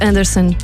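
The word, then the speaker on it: Andresen: É assim que se escreve, mas diz-se Andressen.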